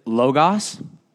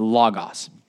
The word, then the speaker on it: logos: Some people will pronounce it logos. That's wrong. It's logos because it's omicron, gamma, omicron. So they say the same thing. It's the same sound. So it's logos.